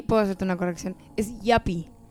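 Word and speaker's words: yuppie: Yapi. Yapi, sí, porque pues, se escribe Yupi.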